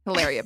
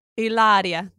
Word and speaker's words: Hilaria: We have some current events that we need to discuss briefly, and that is the hilarious Baldwin. Hilaria Baldwin. – Hilaria. That's how she pronounces that, actually.